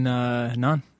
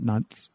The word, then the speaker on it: Nantes: Yeah, actually, I met him in Nantes. Yeah. Yeah. Well, if you speak French, the S is silent. It's not Nantes. N-A-N-T-E-S.